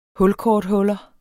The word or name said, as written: hulkorthuller